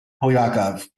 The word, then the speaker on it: Polyakov: Polyakov.